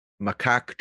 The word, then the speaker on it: macaque: Yaki, I'm sorry if I'm pronouncing that wrong, became a rare alpha female of a macaque troop in a nature reserve, but a kind of simian love triangle may endanger her grip on power.